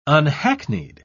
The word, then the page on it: unhackneyed: British English